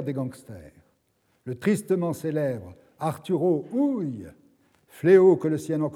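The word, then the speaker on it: Arturo Ui: Hui se prononce en allemand Hui, c'est Arturo Hui qu'il faut dire.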